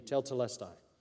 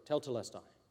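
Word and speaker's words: tetelestai (τετέλεσται): Can you say it, brother? I know it's your favorite word. Thank you, tetelestai. I knew I was going to mess it up. This word, tetelestai, that's it.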